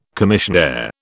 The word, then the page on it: commissionaire: British English